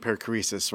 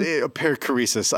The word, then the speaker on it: Perichoresis: So, you know, and that's, you know, we could talk a long time about this, but this whole idea of even perichoresis, right?